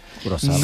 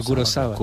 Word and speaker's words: Kurosawa: Que dicen que la pronunciación en japonés es Kurosawa. Kurosawa. No Kurosawa, sino Kurosawa.